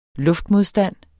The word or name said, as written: LUFTMODSTAND